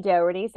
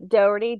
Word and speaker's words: Dougherty: Well, my name is Jeannie Dougherty. So it's spelled with two N's, I-E, kind of like I Dream of Jeannie, this very old TV show. And then Dougherty, D-O-U-G-H-E-R-T-Y.